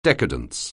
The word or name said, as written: decadence